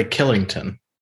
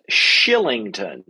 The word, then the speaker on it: Kylington: I'm not sure if you're familiar with the gentleman, but how would you say that name? K-Y-L-I-N-G-T-O-N. – Probably Killington. – It is Shillington. – Oh, wow. Okay. – Yeah. I said Kylington, and I was wrong.